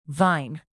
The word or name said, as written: vine